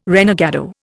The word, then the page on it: renegado: Indian